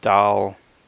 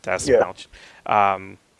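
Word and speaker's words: Dastmalchian: Javelin, Mei Ling Ying as Mongal, Pete Davidson as Blackguard, Sean Gunn as Weasel, and I always pronounce his name incorrectly, David Dalmachian? – Dasmalchian.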